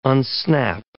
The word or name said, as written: unsnap